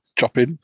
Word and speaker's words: Chopin: Chopin. – Chopin.